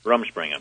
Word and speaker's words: Rumspringa: So now, tell us about Rumspringa. Did I say that right? – Rumspringa.